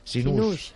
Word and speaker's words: Sinou: ¿Cómo has dicho que se pronunciaba? – Sinou. – Claro, yo tengo que decir Sinou porque si no la peña no va a localizar en las redes sociales.